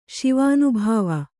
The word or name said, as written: ಶಿವಾನುಭವಿ